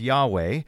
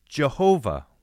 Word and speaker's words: Yahweh: Pastor Paul, in the second half of that episode, you talked a fair bit about the name of God and you pronounced it as Yahweh, but in some older translations, the name of God is pronounced as Jehovah.